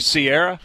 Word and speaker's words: Ciara: I said Ciara, right? – Yeah. – And it's actually Sierra? – Yes.